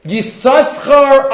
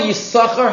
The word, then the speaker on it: Yisachar: Says Rav Kasher, there are three suggestions by the Rishonim why Yisachar has a silent second sin.